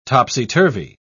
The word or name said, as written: topsy-turvy